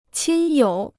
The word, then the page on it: 亲友: qīn yǒu